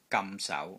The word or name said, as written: GAM6 SAU2